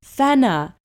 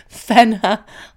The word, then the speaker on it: Fenna: You could tell people Fenna. It rhymes with antenna.